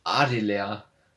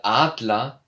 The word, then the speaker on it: Adler: Ähm, beispielsweise bei dem Wort Adeler. Statt Adler hat er das D wie ein D ausgesprochen, also A-D-Leer.